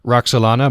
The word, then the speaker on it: Roxelana: Roxelana, am I pronouncing that correctly? – You are.